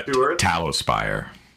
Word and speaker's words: Talospire: Two, to clarify, they said Talospire. – Yeah, Talospire. Like candle tallow.